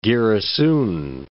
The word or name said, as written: Giresun